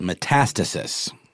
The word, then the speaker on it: metastasis: This growth actually destroys surrounding tissue and can spread to other parts of the body through a process called metastasis.